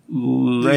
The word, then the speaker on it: Lernaean: So now we got the second task, the Lernaean? – Lernaean. Yep, I'm pretty sure you have to pronounce it exactly like that, too.